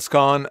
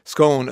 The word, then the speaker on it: scone: When I came home, I used to pronounce scone as scone, and people used to look at me, so I went back to saying scone as phone.